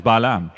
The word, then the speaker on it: Balaam: Digan Balaam. Ese era su nombre. Así es como lo pronunciamos, Balaam.